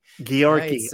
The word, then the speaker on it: Georgi: Yeah, no, I'm from Bulgaria, so the Bulgarian way of pronouncing it is Georgi. – Georgi. Yeah, oh yeah, Georgi, like a Yorkie, but Georgi.